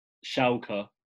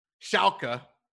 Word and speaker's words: Schalke: And then how do I say Schalke? – Schalke. – Schalke. – Yep.